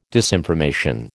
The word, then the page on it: disinformation: American English